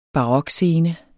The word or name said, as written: barokscene